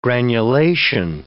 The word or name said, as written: granulation